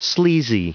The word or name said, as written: sleazy